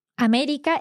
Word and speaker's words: América: América is also esdrújula, mm-hmm, and it also has an accent. So América.